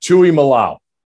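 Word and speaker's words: Tuimoloau: I got Tui Malau. – That's what it is,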